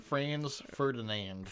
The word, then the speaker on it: Franz Ferdinand: Franz Ferdinand. – Ferdinand's. It's just the way I have it. There's no S at the end of it.